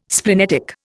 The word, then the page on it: splenetic: Indian